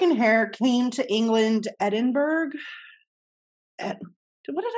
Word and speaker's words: Edinburgh: You gotta say Edinburgh. That's how it's said. I'll say Edinburgh. – Yeah, it's Edinburgh.